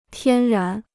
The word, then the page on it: 天然: tiān rán